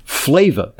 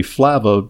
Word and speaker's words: Flava: It's spelled F-L-A-V-A. And I was tempted in the past to pronounce it Flava, because it's got a lot of flavor, but it's actually Flava, which is named after its high flavanol content.